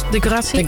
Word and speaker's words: Degrassi: Zeg ik het goed eigenlijk? Degrassi? – Degrassi. – Degrassi, ja.